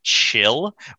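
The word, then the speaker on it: Kchil: His name was, I feel like the only way to pronounce it is Chill, but it's K-C-H-I-L, so it's like chill. Chill.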